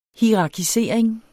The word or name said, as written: hierarkisering